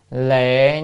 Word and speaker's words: lệ: Rồi, tiếp theo liệt hay là chữ này đọc là lệ như sát giới trung thuyết.